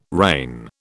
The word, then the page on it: rein: British English